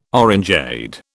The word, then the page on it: orangeade: British English